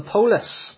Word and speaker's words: polis: It's also the word that we get police from, or if you came from Glasgow, you would actually pronounce it in the Greek way. You would call them the polis.